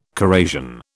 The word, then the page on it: corrasion: British English